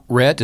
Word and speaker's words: Rhett: Rhett is R-H-E-T-T. Laubach is L-A-U-B-A-C-H.